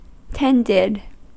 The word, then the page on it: tended: American English